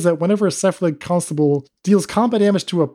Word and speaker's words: Cephalid Constable: And another list that was even weirder was like a Bant Cephalid Constable deck. Am I saying this right? – Yeah. – And even just like uttering these words, you know you're in for some nonsense. Cephalid Constable, one blue blue, Cephalid Wizard.